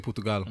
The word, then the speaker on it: Portugal: You have to say Portugal. – Yeah, that's how they say it over there.